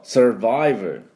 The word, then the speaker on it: Survivor: まあ、僕はちょっとなんかに守られたんじゃないかとちょっと思ってるんですけども、まあまあそれ置いといて、この Survivor の、発音ですが、この最初の Sir のところは Cheers で、で Viver ですね、 V ですから軽く下の唇を、歯に当てて、あのエアアクセルウェイが振動します。